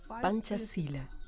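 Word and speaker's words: Pancasila: Panchasila Así es como se debe pronunciar.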